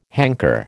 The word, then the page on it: hanker: American English